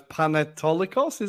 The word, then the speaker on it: Panetolikos: Panetolikos, is that right? – Yeah, correct. Uh, yeah, that's right.